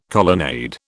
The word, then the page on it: colonnade: British English